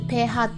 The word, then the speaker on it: pH: PH って呼んでペーハーって読